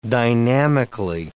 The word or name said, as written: dynamically